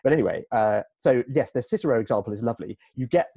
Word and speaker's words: Cicero: which is Cicero's tweets. Although, of course, Cicero would have pronounced his name Cicero, because all C's in Latin were hard.